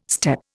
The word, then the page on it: steppe: Indian